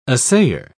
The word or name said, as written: assayer